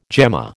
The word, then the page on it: gemma: American English